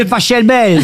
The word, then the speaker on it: Pachelbel: Canelloni, c'est Pachelbel. – S'il est allemand, ça se dit Pachelbel.